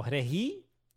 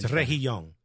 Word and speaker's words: Reguilón: Reguilón, but I don't think you're supposed to pronounce the G. – It's Reguilón. You do, there's a G there. Oh, Regi? – Yes, no, Reguilón. – G-U-I is G.